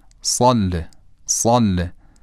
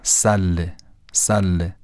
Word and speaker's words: صله: مثلا میگم اللهم صل على محمد و آل محمد، صله صله، و نمیگم سله سله. این درشتی صاد رو صاد میکنه.